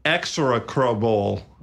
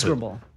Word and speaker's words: execrable: It is an execrable, I mispronounced execrable. – It. Execrable? – Sure.